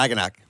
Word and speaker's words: Ragnarok: I don't even know how to say the name. – Yeah, Ragnarok.